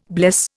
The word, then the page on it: bless: Indian